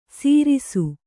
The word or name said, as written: ಸೀರಿಸು